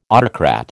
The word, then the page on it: autocrat: American English